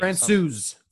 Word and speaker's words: Francouz: Francouz.